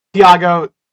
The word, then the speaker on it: Tiago: Tiago, you know what? There'll be like a Twitter post. My name will be there probably. – Your name is spelled how it sounds.